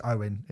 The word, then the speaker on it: Eoghan: So Eoghan is Owen, it's pronounced, but I'm going to call him Eoghan.